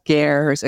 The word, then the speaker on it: ger: So this is a place that is built in the middle of the desert in these, they're pronounced gers. A ger is a Mongolian word for a yurt.